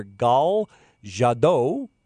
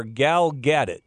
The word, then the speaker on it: Gal Gadot: Now, the woman that has been selected is a Israeli actress by the name of, and a couple pronunciations. It's either Gal Gadot or Gal Gadot, depending on what part of the country you're from. Anyway, she's been selected, and here's my problem.